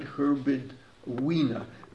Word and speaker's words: Herbert Wiener: Of course, at the time, now let me pronounce his name right, Herbert Wiener.